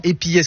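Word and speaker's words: épillet: Alors, est-ce que je le dis bien? Épillé. Voilà, épillé, ce que j'ai dit. Épilé, moi. Rien à voir avec les rasoirs.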